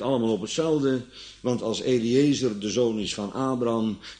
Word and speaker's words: Eliezer: En in nog ander geval is Eleazar, uitgesproken als Eliezer in het Nederlands, de zoon van Abraham.